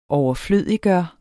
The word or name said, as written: overflødiggøre